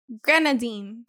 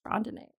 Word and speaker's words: grenadine: Grenadine. – Grenadine. I thought it was grandinate.